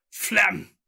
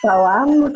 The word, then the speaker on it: Phelan: Phlegm. – Phelan? It's not phlegm. I know it's not.